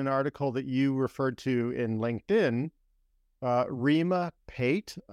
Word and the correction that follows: Patel: Am I pronouncing that correctly? Pate? I don't know. – Rima Patel. – Anyway, Rima Patel.